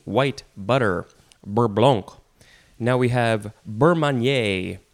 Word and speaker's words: beurre blanc: The first word is beurre blanc. Beurre blanc. I don't think you should say the C sound at the end. It is spelled B-E-U-R-R-E. Next word, B-L-A-N-C.